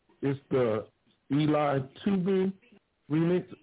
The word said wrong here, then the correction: Eli Tubo: Is that how you pronounce his name? – It's what? Eli Tubo.